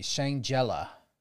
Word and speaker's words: Shangela: Divas for Democracy, Shangela. – Oh, Shangela. – Is that how you say it? – Uh-huh.